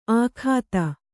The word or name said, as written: ಆಖಾತ